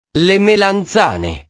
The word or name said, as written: le melanzane